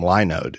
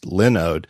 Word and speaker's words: Linode: I used to call them Linode because that's what it looks like to me, Linode, but it's actually pronounced Linode because it's a node, like a thing, like a server, and they host Linux, which is pronounced Lin.